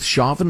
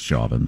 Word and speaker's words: Chauvin: Yeah, I'm going with Chauvin myself. – Yeah. His own attorney, it says Chauvin.